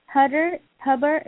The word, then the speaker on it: Hubbard: Hubbard is H-U-B-B-A-R-D.